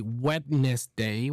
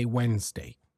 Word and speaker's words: Wednesday: Lastly, the first D in the word Wednesday seems to have taken the day off as well. So we don't say Wednesday, we say Wednesday. Okay, we don't even pronounce the second E.